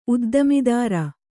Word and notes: ಉದ್ದಿಮೆದಾರ: Kannada; uddimedāra

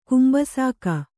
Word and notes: ಕುಂಬಸಾಕ: kumbasāka

